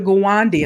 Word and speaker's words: Gawande: It says, in this week's New Yorker, Dr. Gawande, and I hope I pronounced that right.